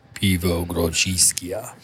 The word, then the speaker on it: Grodziskie: We've got how do you say it? The Pivo? – Pivo Grodziskie. – Yes. Is very excited about the correct pronunciation of that historical style of beer, but it's a Polish beer that's mm-hmm.